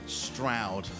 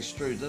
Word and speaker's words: Stroud: He's now in Nailsworth, which is just outside of Stroud. Rhymes with loud. Thank you, very kind. I always say Strood. I don't know why. It's Strood in Kent, actually. That's why.